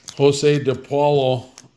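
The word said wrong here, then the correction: José De Paula: Yo Sway DePaula is how you would say.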